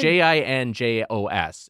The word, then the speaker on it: Jinjos: When has a J ever been pronounced as a hard G? If it's J I N J O S, it's Jinjos.